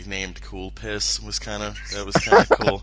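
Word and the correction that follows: Calpis: Your pronunciation is slightly off. I believe you're talking about Kalpis.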